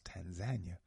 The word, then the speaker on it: Tanzania: Many people pronounce it Tanzania, but the brother said it's pronounced Tanzania by the brothers and sisters there. So to me, if that's how they pronounce it, that's what it is, Tanzania.